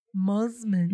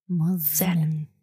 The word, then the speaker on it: Mosman: It is Mosman. Mos- You have to say it. It's spelled with an S, but almost like a Z or Z. Mosman.